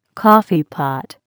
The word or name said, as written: coffeepot